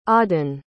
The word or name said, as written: audun